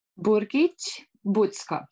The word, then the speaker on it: Burgic Bucko: Burgic Bucko.